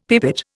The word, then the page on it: pipit: Indian